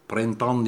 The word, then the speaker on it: Printemps: Printemps diye okunuyor ya.